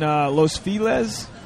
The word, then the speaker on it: Los Feliz: We're at Home in Los Feliz. Am I saying that right? – Yeah.